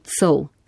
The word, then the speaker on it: Col: In če torej besedo sol, zapisano sol, izgovarjamo tako, potem po analogiji tako izgovarjamo tudi col, sicer cola, ampak col in potem colski, colčan, colčanka.